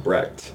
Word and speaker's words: Brecht: Is it Brecht or Brecht? – Whatever. I've always heard Brecht.